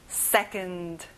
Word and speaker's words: second: 여기도 역시 앞에 강세를 넣어주면 좋아요. second.